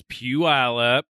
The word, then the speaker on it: Puyallup: It's Puyallup. – Do people say Puyallup? – I've heard Puyallup. – Yeah, like people that are from here, well, at least from Seattle. – Yeah, they say Puyallup.